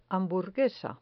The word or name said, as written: hamburguesa